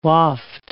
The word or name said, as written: waft